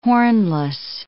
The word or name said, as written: hornless